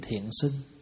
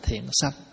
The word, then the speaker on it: Thiện Sinh: Thì hôm nay mình học một bài kinh tên là Kinh Thiện Sinh. Có chỗ đọc là Thiện Sanh, sanh hay sinh gì cũng được.